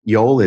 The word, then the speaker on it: Yol: Sure, Yol is Y-O-L, and it sounds as it's spelled. Yol rhymes with soul, and it's Turkish for the path, the road, or the way.